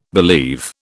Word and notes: believe: British English

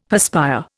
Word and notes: perspire: Indian

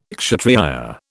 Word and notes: Kshatriya: British English

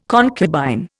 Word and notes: concubine: Indian

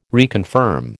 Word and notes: reconfirm: American English

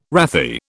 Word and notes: wrathy: British English

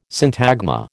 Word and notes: syntagma: American English

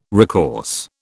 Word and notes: recourse: British English

